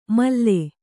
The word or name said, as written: ಮಲ್ಲೆ